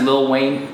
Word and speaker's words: Lil Wayne: I think it's pronounced Lil Wayne. Gotta get. – I did say Lil Wayne. – Yeah, it sounded like you had the extra syllable in there.